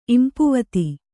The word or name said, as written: ಇಂಪುವತಿ